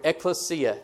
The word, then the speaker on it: ekklesia: The word church, we've already talked about it in week one, is from the Greek word ekklesia. Let me spell that for you if you're taking notes. E-K-K-L-E-S-I-A.